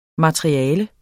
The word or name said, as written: materiale